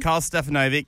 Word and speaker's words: Karl Stefanovic: Now, Karl Stefanovic. Did I say it right that time? – Karl Stefanovic. Yeah, yes, look, he's been in a bit of trouble.